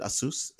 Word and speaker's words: Asus: But according to Asus, that's how you pronounce it, Asus,